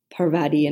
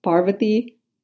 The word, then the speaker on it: Parvati: People say Parvati and Padma. It's Parvati and Padma. So it's just like putting the emphasis on different syllables,